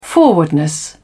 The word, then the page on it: forwardness: British English